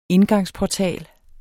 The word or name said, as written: indgangsportal